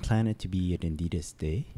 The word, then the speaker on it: Adidas: Can you remind me what is the proper way to say Adidas? – Adidas. – Is the S pronounced? Adidas. – Yeah, you could pronounce. But what matters is the A. – Adidas. – Adidas. It's the German way.